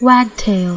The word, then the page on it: wagtail: American English